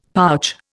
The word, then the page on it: pouch: Indian